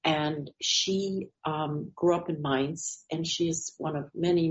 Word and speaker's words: Mainz: Mainz.